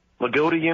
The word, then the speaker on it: Loogootee: Loogootee, Indiana.